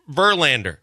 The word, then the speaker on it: Verlander: And you can't get it that it's Verlander. Every single time the emphasis is on the wrong syllable.